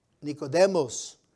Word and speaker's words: Nicodemus: Nicodemus. And that's the way you pronounce it.